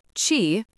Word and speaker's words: qi: Qi, pronounced qi, is the foundational concept, not merely energy, but the substance connecting all existence.